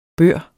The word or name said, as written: bør